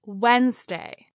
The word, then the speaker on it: Wednesday: And lastly, we have Wednesday, the third day of the work week, as Wednesday, not Wednesday.